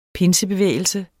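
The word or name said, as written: PINSEBEVÆGELSEN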